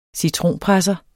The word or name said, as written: citronpresser